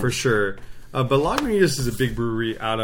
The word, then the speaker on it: Lagunitas: Is it how you pronounce it? Lagunitas. – Lagunitas.